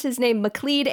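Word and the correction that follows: MacLeod: Andrews, and then immediately after recording, I was like, oh, his name is fucking McLeod. It's McLeod. It's McLeod Andrews. It's pronounced McLeod.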